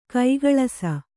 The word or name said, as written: ಕುಕ್ಕುಳಿಸು